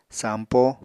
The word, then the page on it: sampo: apatani